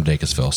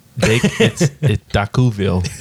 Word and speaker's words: Dacusville: Well, we're not from Dacusville, so. – It's Dacuville. – Oh, my bad. Sorry. – The S is silent. It's fancy.